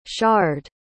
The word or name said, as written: sharde